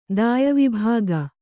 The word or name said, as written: ದಾಯವಿಭಾಗ